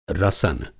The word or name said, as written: रसन